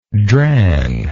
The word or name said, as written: drang